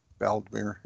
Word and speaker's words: Vladimir: Vladimir is how you pronounce that.